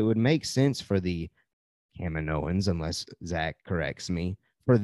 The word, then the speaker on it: Kaminoans: We have finally learned how to correctly pronounce that, by the way. Thank you, Rebel Scum Texan. – Yeah, again. Kaminoans.